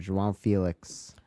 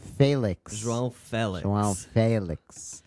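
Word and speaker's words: Félix: He's saying they only say it right in Portugal. But you got to realize, brother, that everywhere else in the world, they say Felix.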